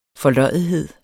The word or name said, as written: forløjethed